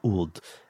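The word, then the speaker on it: Ud (ʿĀd): So Ubar has long been associated with a group of people called the Ud, spelled like apostrophe A-D, but pronounced more like Ud.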